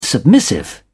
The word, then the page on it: submissive: British English